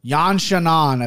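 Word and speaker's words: Yan Xiaonan: Yan Shannon, I think is how they pronounce it. – I think that's right. – I think I've heard Anik say that. I mean, he says it way better than me, but that's kind of how you pronounce it.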